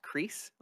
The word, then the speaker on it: Cris: I think it's pronounced Crease, like C-R-I-S.